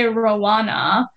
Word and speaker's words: Rowena: But everybody there seemed to pronounce my name as Rowena. That was in school, or in like for Kenyans, like locals, they would say Rowana.